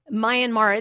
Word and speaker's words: Myanmar: Myanmar.